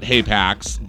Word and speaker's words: HAPAX: And by the way, it is spelled H-A-P-A-X. I do occasionally accidentally call it HAPAX, but it's HAPAX, right? – Yeah.